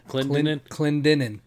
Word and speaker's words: Clendenin: Clendenin. – That's how it's pronounced. – How's it pronounced? – Clendenin. – Clendenin.